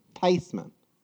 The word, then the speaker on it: Theismann: Actually, the correct pronunciation is Theismann. So it's a lot closer.